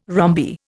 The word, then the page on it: rhombi: Indian